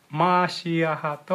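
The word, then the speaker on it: マ ー シ ア ハ (מָשִׁיחַ): 正確にはマーシアハと発音します。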